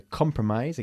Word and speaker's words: compromise: You have to compromise, again, emphasizing the first syllable.